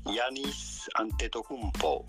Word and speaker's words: Giannis Antetokounmpo: Simply, Giannis Antetokounmpo. Giannis Antetokounmpo.